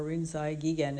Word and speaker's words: Rinzai Gigen: And now today I'll take his counterpart, Linxi Yixuan, or Rinzai Gigen as his name is pronounced in Japanese.